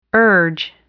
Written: urge

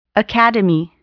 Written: academy

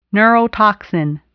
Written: neurotoxin

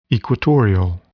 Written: equatorial

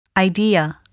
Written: idea

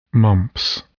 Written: mumps

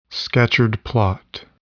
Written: Scatchard plot

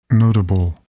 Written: notable

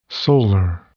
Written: solar

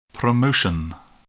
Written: promotion

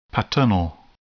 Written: paternal

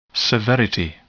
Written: severity

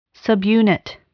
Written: subunit

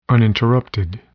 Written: uninterrupted

